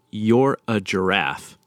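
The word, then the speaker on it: Yirgacheffe: And they told me, I think that it's You're a Giraffe. – You're a Giraffe. – And that's kind of how you kind of... slur it all together, and you're a giraffe.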